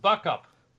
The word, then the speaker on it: Beaucoup: There's a town near there that is French, is named after a French word, B-E-A-U-C-O-U-P, beaucoup. It's beaucoup. Pronounced buck up.